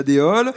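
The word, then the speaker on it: Déols: Déol, ça s'écrit D-E-O-L-S, est-ce qu'on n'entend pas donc, et elle fait partie de Châteauroux Métropole.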